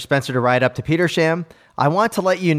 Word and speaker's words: Petersham: Looking at you, Tim, and pronounced the town name Petersham like you. I was quickly corrected by a townie. It's Petersham, Flatlander.